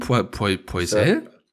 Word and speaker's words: Poisel: Wie, so wird der ausgesprochen? Poisel? – Ja.